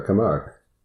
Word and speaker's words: Camargue: Am I pronouncing Camargue, in the south of France, sure, Camargue.